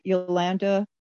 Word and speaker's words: Jolanta: Okay, Yolanda Bodner. – Can you spell it? – J-O-L-A-N-T-A.